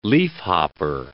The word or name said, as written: leafhopper